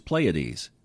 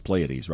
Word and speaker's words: Pleiades: The typical American pronunciation is Pleiades and the British is Pleiades. You know what he responded with? Well, you know it's Pleiades, right? But he spelled it P L A Y A T E A S E.